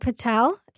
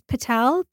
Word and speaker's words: Patel: I'm under Alpa Patel. – Can you spell it for me? – A-L-P-A. Last name is Patel, P-A-T-E-L.